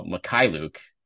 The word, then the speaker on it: Mykhailiuk: So the Basketball Reference pronunciation, first of all, Mykhailiuk is the emphasis of the emphasis on the K in the middle there. Which is a real curveball when you look at it, you look at the last name.